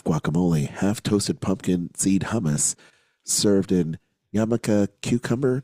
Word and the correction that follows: jicama: Did I say that right? – No, jicama. – Oh, jicama, jicama. hickama Jicama cucumber, tortilla chips, chipotle salsa, and tomatilla salsa contain seeds, y'all.